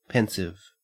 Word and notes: pensive: Australian English

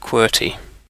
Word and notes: qwerty: British English

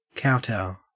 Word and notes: kowtow: Australian English

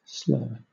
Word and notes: slur: British English

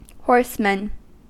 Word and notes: horsemen: American English

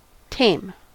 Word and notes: tame: American English

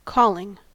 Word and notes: calling: American English

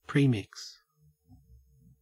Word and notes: premix: Australian English

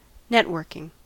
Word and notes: networking: American English

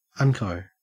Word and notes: unco: Australian English

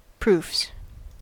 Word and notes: proofs: American English

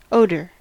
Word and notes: odor: American English

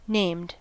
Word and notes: named: American English

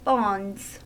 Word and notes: bonds: American English

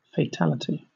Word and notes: fatality: British English

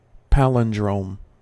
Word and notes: palindrome: American English

